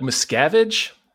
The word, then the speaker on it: Miscavige: Is that how we're pronouncing it, Miscavige? – I've always heard it like Miscavige. – So have I. – Like, I think that's how they said it in Going Clear.